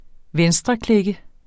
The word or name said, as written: venstreklikke